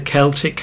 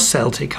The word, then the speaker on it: Celtic: That was Claire Hamilton with Shepherd Moons played on the Celtic harp, or Celtic harp if that's the way you wish to pronounce it.